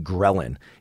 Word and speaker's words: ghrelin: And it's called ghrelin.